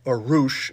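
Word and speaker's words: Rush: Roosh is how it's pronounced.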